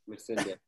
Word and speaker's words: Missandei: Missandei.